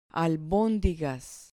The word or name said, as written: albondigas